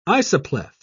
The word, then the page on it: isopleth: British English